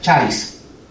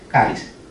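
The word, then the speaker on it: charis (χάρις): La palabra, la palabra griega para gracia en inglés se pronuncia charis. En español sería caris. De ahí viene la palabra en inglés charity.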